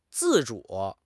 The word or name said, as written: 自主